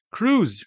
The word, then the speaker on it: cruise: cruise 是 cruise, 就是就是汤姆克鲁斯那个 cruise。好，就是汤姆克鲁斯那个 cruise。那 cruise 一般的发音的那个 S 是发成 Z 的音啦，只是说有时候听不太出来这样而已。